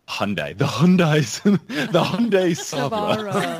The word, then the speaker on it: PSOVRA: P-S-O-V-R-A. So he's a Savra, you know, silent P, like pterodactyl.